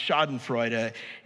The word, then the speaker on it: Schadenfreude: It's pronounced Schadenfreude, and it literally means something like pain, joy, or harm, or damage, joy.